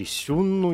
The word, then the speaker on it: сюнну: Говорим о племенах э, хунну или сюнну, ежели использовать да, китайское э, произношение.